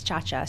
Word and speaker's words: Ciaccia: So since then, I realized that the correct pronunciation is Ciaccia.